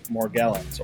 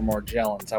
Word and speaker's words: Morgellons: I mean, I thought that we were just mainly going to talk about Morgellons or Morgellons. I wasn't even sure how to pronounce it. – It's either way.